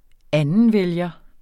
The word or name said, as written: andenvælger